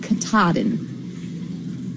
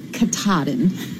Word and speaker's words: Katahdin: Just so that Lamar knows, the correct pronunciation of the mountain in Maine on the Appalachian Trail is Mount Katahdin. You have to say it with a roll on your tongue, Katahdin.